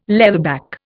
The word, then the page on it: leatherback: Indian